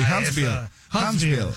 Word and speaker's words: Huntsville: Huntsville, Chiri, ¿sí lo dije bien, o no? – Sí, sí, sí, Huntsville.